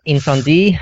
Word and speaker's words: Incendies: which is Incendiaries or Incendies? – Incendie, I think. – Incendie, French. Okay, so is it French? – The French pronunciation is, yeah.